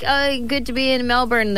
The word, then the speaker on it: Melbourne: I love Melbourne.